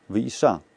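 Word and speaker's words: Ваиша (וַיִּשַׁע): Ваиша. Там ударение Ваиша, в суке Ваиша.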